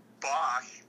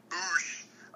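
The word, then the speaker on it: Bossk: And I just today, talk about timely, I just debuted a poem about the bounty hunter Bosch or Boosh, depending on your pronunciation.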